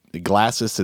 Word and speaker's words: Glasys: Glacis is spelled Glasys.